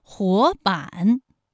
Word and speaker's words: 活版: 活版，第一个字是二声，第二个字是三声。